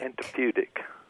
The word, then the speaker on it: antipudic: You spell it A-N-T-I-P-U-D-I-C. Antiputic. – Antiputic.